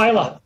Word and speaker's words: Vejle: But obviously he comes, he's born in Vejle, or hopefully I pronounced that even almost. – Yeah, Vejle.